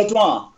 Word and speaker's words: Ledroit: Is it Ledroit? Is that how you say it? – Yeah, Ledroit.